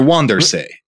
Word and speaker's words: Vanderzee: That's how they spell it over there. They're called, they're Wandersee. There are lots of Wandersees in Holland.